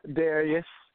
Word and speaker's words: Darius: Darius.